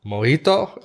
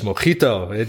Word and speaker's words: mojito: Dus ik dacht, hey James, het is mojito. Die J, die spreekt als G uit. Maar het schijnt dus dat op Cuba dat juist wel weer als een soort H wordt uitgesproken.